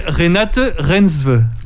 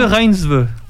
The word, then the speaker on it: Renate Reinsve: Non, elle s'appelle Renate Reinsve. – Très bien, très belle prononciation. – Renate Reinsve.